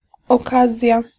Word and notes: gelegenheid: [ɣǝ.ˈle.ɣǝn.ɦɛjt]

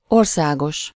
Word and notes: national: American English; /ˈnæʃ(ə)nəl/

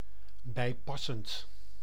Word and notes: conforme: France; /kɔ̃.fɔʁm/

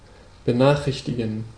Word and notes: benachrichtigen: /bəˈnaːχʁɪçtiɡən/; [bəˈnaːχʁɪçtʰɪɡŋ̍]

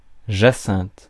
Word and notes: jacinthe: France; [ʒa.sɛ̃t]

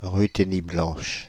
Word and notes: Ruthénie blanche: [ʁy.te.ni blɑ̃ʃ]; France (Île-de-France)

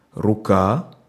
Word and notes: рука: [ru.ˈka]; ruka

